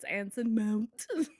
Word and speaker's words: Anson Mount: have to say his name that way. I'm sorry, it's Anson Mount.